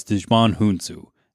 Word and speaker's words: Djimon Hounsou: Djimon Hounsou. And you're like, wow, what alien was he? No, that's actually how you pronounce this actor's name.